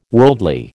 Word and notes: worldly: American English

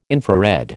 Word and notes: infrared: American English

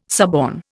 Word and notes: suborn: Indian

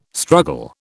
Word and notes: struggle: British English

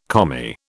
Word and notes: Commie: British English

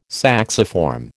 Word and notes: sacciform: American English

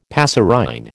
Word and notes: passerine: American English

